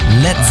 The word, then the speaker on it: Led: Led Zeppelin, das A in Led ließ er einfach weg, damit es nicht wie Blei ausgesprochen wird.